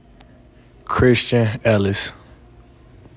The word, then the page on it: Christian Ellis: KRIS chin EL iss